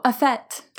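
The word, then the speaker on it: fête: Tell me if I pronounce this right. Fete? – Oh, a fete. – Fete. Thank you.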